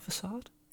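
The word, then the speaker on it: façade: I pronounce it facade, but to go into my Frenchiness, it's because in French it would have a little cedilla under the C, which would mean that it was a sur sound.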